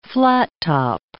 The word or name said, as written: flattop